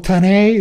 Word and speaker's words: Tanei: So it's called Tanay, it's spelled Tanei, and I heartedly recommend it.